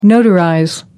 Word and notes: notarize: American English

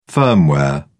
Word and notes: firmware: British English

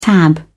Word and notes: tab: British English

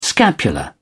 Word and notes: scapula: British English

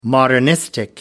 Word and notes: modernistic: American English

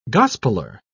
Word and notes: gospeler: British English

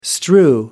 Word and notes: strew: American English

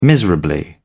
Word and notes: miserably: British English

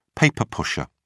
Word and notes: paper-pusher: British English

